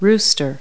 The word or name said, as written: rooster